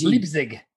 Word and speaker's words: Leipzig: Leipzig. – Leipzig.